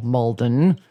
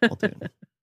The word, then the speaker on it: Muldoon: And be like, you're putting the emphasis on the wrong syllable, Mulden. – Yeah. – So, what did Joe tell you? Oh, God. History's worst acting robot, Patrick Muldoon.